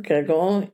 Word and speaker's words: Gauguin: Gauguin is 69, is that how you pronounce it? – Yeah.